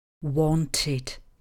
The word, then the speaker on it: wanted: Výjimku tvoří slovesa končící na hlásku t nebo d. U nich v koncovce ed, česky ed, samohlásku musíme vyslovit. Vyslovujeme ji jako i. Proto u slovesa to want, chtít, bude minulý tvar znít. Wanted.